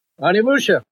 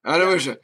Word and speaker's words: Onimusha: You said I pronounced it right earlier, so I'm going to try to do it again. Onimusha? – Onimusha. – Onimusha.